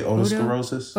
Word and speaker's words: otosclerosis: But right now, I have some questions about your otosclerosis. Is that how you say it? – Yeah. I usually say otosclerosis.